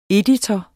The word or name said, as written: EDITOREN